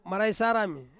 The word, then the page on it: maraisarami: Ansus